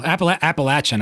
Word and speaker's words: Appalachian: The Appalachian Trail, Appalachian. I'm sorry, I pronounced it wrong.